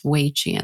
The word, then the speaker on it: Wei Qi: Wei Qi, and that's spelled W-E-I, and then qi is Q-I.